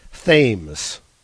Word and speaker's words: Thames: Suko from New York comments, Thames. – yeah. – So the silent H.